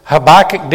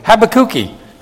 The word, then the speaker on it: Habakkuk: Sounds like have a cookie, but Habakkuki. That's Portuguese for Habakkuk or Habakkuk. And you say, which one is correct? I said, well, it's what the Hebrew says, but we pronounce it differently in different languages.